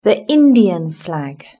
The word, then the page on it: the indian flag: British English